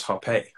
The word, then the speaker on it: Hoppe: I didn't realize it was Hoppe. So it's a two-syllabled.